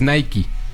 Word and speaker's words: Nike: Entonces tuvieron que hacer toda una campaña para cambiar la pronunciación, porque el correcto es Nike, ¿no? Entonces, o sea, desde siempre. – A nivel mundial siempre ha sido Nike.